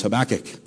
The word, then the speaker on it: Habakkuk: I can't even pronounce it sometimes, but it's Habakkuk.